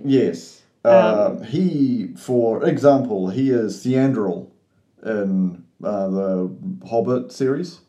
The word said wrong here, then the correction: Thranduil: It's T-H-R-A-N-D... Yeah. – What did you say? – I don't know. – Thranduil. Thank you. I was like, excuse me, what is this word you're saying? – He's also...